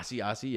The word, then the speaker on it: Asiasi: And you have to say, I don't even know how to say his name. Asiasi? – Yeah, Asiasi, yeah.